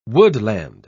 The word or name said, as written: woodland